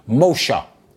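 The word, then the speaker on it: Moshe (משה): Actually, the name, it literally means, it's pronounced Moshe.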